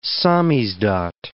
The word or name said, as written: samizdat